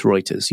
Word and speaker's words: Reuters: Yeah, I've also heard people say that as Reuters, which is not the correct pronunciation as far as I'm concerned. It's Reuters. You said it correctly, Chris.